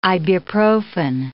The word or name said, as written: ibuprofen